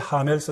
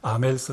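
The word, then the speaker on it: Hamel: 이때 하멜 선생님이, 불어로는 아멜이라고 해야 됩니다. 아쉬를 발음 안 하기 때문에 아멜 선생님이 이렇게 말했는데, 이것이 너무나 너무나 언어와 관련해서는 유명한 말입니다.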